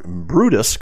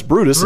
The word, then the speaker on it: Brutus: Anthony fails to capture Mutina and Decimus Brutimus. Brut, Brutus. God, I did butcher it. Decimus Brutus.